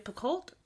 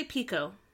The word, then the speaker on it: Picoult: I've been pronouncing it Jody Picoult mm-hmm. – with a T and how it sounds. Nope, she said it's Jody Pico.